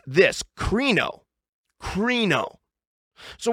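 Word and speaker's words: krino (κρίνω): But I listened to it on a Greek Bible website, and that's how they pronounced it, krino.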